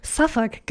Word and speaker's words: Southwark: Und der Track heißt Southwark. – Suffolk, glaube ich. Southwark? So wird das auf Englisch ausgesprochen. – Okay, auf britischem Englisch. – Auf britischem Englisch, ja.